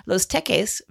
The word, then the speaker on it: Los Teques: So Miguel Rojas is the shortstop for the Marlins, and he is from Los Teques, Venezuela. Did I say that right? – That sounds good.